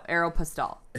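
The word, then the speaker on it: Aéropostale: But technically, the first thing they teach you is how to say it, and it's Aeropostale. No, Aeropostale.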